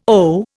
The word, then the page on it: owe: Indian